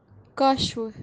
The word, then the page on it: qashwu: Kashmiri